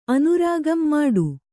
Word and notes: ಮುಟ್ಟ: Kannada

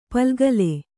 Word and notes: ಪುಲ್ಗಲಿ: pulgali

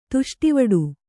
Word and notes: ತುಷ್ಟಿವಡು: Kannada

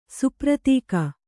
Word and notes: ಸುಪ್ರತೀಕ: supratīka

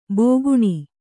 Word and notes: ಬೋಗುಣಿ: bōguṇi

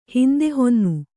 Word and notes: ಹಿಂದೆಹೊನ್ನು: kannada

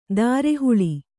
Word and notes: ಧಾರೆಹುಳಿ: dhāre huḷi

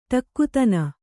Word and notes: ಟಕ್ಕುತನ: ṭakkutana

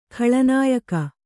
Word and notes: ಖಳನಾಯಕಿ: khaḷa nāyaki

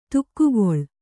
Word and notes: ದೊಗ್ಗಳೆ: doggaḷe

